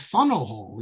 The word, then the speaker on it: Faneuil Hall: Or how about Faneuil Hall? As my father, an American historian, has told me originally, the proper pronunciation is Funnel Hall, even though it, that's not what it is.